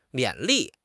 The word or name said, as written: 勉励